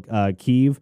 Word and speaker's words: Kyiv: So right here, of course, we've got Russia over here and the capital, Kyiv, in Ukraine, which is how the Ukrainians pronounce it. So that's how I'll continue to pronounce it from here on out.